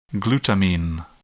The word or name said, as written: glutamine